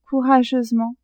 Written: courageusement

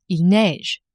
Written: il neige

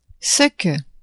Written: ce que

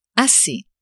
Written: assez

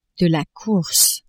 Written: course

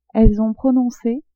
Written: elles ont prononce

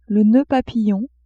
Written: noeud papillon

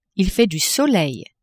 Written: il fait du soleil

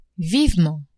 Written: vivement

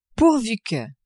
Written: pourvu que

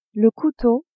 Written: couteau

